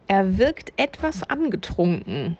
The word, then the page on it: angetrunken: German